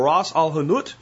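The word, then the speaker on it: Ras el Hanout: Again, it's called Ras Al Hanout. It's not spelled that way, but that's how you say it.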